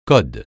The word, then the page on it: code: francais